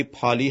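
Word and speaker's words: Paley: از جمله پیلی، حالا گاهی پالی هم گفته میشه ولی ظاهرا تلفظ درستش همون پیلی هست، ایشون مده جهان رو تشبیه میکنه به یک ساعت.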